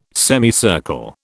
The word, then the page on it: semicircle: British English